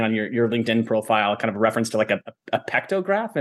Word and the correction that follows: partograph: Partograph. – Partograph.